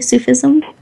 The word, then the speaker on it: Sufism: She's a graduate of the University of Spiritual Healing and Sufism. Am I saying that correctly? Sufism? – Yes.